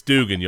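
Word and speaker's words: Duggan: As far as pouring one out, although it's Dugan, y'all, Max Dugan, say it right.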